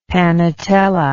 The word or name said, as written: panetella